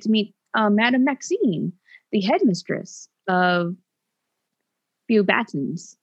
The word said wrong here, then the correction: Beauxbatons: Beauxbatons. Wow, yes. It's French.